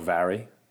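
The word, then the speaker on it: Mhairi: And my sister, she's called Vary. – Okay. – And that's spelled M-H. – Okay. – A-I-R-I. And it means Mary.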